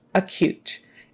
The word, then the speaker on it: acute: Just remember that cute part, how you say it when someone is cute, it's with a K-Y-U sound, like cute, not coot, cute, acute. And the stress is on the second half, acute, meaning very serious, right?